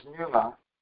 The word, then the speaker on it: pneuma: It starts with the letter P, but you don't pronounce the letter P. It's pneuma.